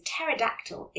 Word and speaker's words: pterodactyl: Because the P is silent. That's because pterodactyl is spelt with a silent p at the beginning.